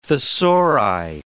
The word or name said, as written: thesauri